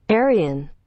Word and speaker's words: Arine: We have Carl, Anna, and A-R-I-N-E. How do you pronounce that, Google? – Arian.